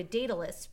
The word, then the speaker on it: Daedalus: But anyway, so they figure out that she can go to Daedalus.